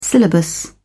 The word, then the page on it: syllabus: British English